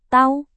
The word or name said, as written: tau